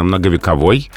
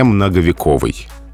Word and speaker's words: многовековой: Словари настаивают, что правильно многовековой, и даже делают специальную запретительную помету «не многовековый» то есть ударение на окончании, что история многовековая, что дуб многовековой, во всех случаях так.